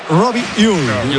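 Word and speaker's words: Robbie Ure: A ver, el club pasó incluso un audio, lo ha pasado. Y en concreto sería Robi Ui. Robi Ui.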